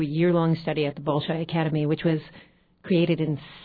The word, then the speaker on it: Bolshoi: and also did a summer intensive with the Bolshoi. Am I pronouncing that right? – Yes. – Bolshoi Ballet in New York.